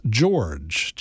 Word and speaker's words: Gyorgy: Her surname is Hungarian, but it's pronounced George, G-Y-O-R-G-Y.